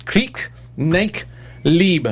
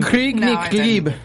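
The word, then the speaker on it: Krieg nicht Liebe: I'm going to say it's Krieg, Nicht, Lieb. I'm trying to sound in my best German. – Krieg, Nicht, Lieb.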